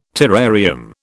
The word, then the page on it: terrarium: British English